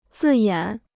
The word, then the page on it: 字眼: zì yǎn